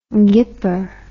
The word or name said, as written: gipper